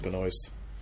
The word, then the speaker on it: Benoist: Pretty sure it's pronounced Benoist. – That stuff's usually silent.